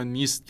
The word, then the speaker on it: NIST: به نام NIST یا نیست که حالا تو فارسی هم تلفظش راحته